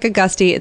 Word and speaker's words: Agosti: It sounds like Agusti. It's spelled like Agosti.